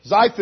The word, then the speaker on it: Ziph: They went to the wilderness of Ziph. Is that how you pronounce that wilderness? Good. All right, good. We'll call it that. Looks good. Ziph it is.